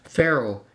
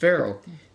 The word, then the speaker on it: Faroe: The next thing, the only landmass that saw the full eclipse was a group of islands in the northern Atlantic Ocean called Faro. It's pronounced just like the Egyptian Pharaoh, but it's spelled F-A-R-O-E. It's pronounced Pharaoh.